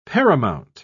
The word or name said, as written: paramount